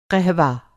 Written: क़हवा

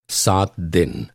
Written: सात

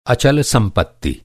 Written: अचल

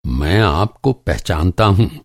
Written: पहचानना

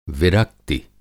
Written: विरक्ति

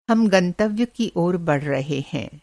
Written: गंतव्य

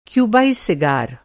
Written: सिगार

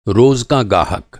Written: गाहक

